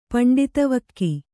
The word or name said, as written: ಪಂಡಿತವಕ್ಕಿ